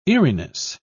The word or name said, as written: eeriness